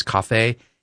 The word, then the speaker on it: Kafe: It looks like it's spelled coffee, but it's Kafe Anderson, part French heritage in his background.